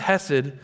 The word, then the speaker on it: chesed: It's the word chesed. If you want to say it right, I think you have to do a little phlegm in the beginning. Chesed.